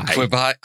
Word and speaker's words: Quibi: Is it Quibi or is it Quibi? – Quibi. I don't care. – Rhymes with ribeye.